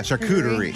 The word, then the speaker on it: charcuterie: Is that how you say it? Charcuterie? – Yeah, charcuterie.